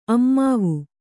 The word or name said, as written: ಅಮ್ಮಾವು